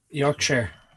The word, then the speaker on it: Yorkshire: No, Yorkshire, not Shire, you dumb American. – Hold on just a second here. Settle this for us. Am I saying this right? Yorkshire. – Yorkshire.